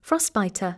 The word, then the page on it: boating: bóutiŋ